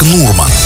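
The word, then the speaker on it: nordmann: В норвежском языке слово «норман» произносится как «нурман».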